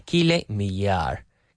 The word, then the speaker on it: Millar: E-M-A, Kyle Millar. K-Y-L-E-M-I-L-L-A-R.